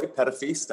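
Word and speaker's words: Petitfils: Well, I'm Roy Petafis. That's how you pronounce my last name. It means grandchild in French.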